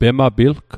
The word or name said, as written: pemapilg